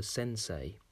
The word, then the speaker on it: sensei: My teacher is called a sensei. To say this out loud, you would say sen, s e n, say, s a y, sensei. But that's not how it's spelt.